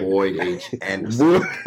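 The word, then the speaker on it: Boyd H. Anderson: And I got to tell you, man, my guy went to the Boys H. Henderson High School. Did I say that right? – Boy H. Anderson. Cobras.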